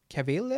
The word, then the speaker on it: Cavill: Och jag tycker verkligen att Henry Cavill, är det så han uttalas efternamnet? Ah, ja.